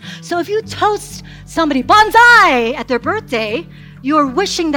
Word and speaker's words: banzai: Banzai.